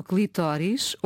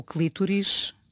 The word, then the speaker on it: clitóris: Estamos a falar do clitóris, ou clítoris, eu vou dizer clitóris, fica já o aviso, se alguém se sentir ofendido, por favor, escrevam-nos. – Mas é bom salientar que neste momento ambas as pronúncias são corretas. E eu digo neste momento porque, tendo eu a idade que tenho, houve uma altura em que oficialmente a correta era clítoris